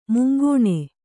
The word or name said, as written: ಮುಂಗಾಣು